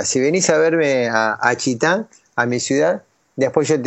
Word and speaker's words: Chita: Entonces bueno, me desvié hasta la ciudad de Chitá, se escribe así como si fuera Chita, pero sin el acento en la A.